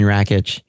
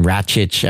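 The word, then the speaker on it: Rakić: I'm going to go with the Serbian Rakic. I'm sorry, I mispronounced his name. Rakic.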